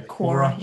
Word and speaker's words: Quora: I think I have a, how do you pronounce that website? Quora? What's the one where they just ask questions? – Yeah, Quora. – Quora, that's the one.